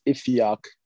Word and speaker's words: Iffy: My name is Ifeok, and I'm from Nigeria. So that's where the name comes from. And I go by Ife for short. So if you can't pronounce Ifeok, which is spelled I-F-I-O-K, you could just call me Ife, I-F-F-Y.